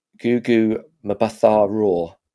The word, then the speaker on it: Gugu Mbatha-Raw: It stars I'm not very good at saying her name, Gugu Mbatha-Raw. Is that how you say it? – Yeah.